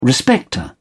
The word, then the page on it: respecter: British English